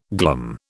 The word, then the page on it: glum: British English